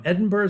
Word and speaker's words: Edinburgh: Delighted to welcome to our microphone Professor George Callaghan, all the way from Edinburgh, Scotland.